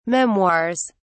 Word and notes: memoirs: British English

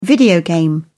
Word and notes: video game: British English

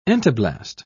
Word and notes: entoblast: British English

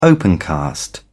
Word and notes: opencast: British English

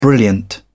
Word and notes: brilliant: British English

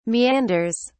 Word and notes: meanders: British English

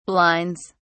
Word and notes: blinds: British English

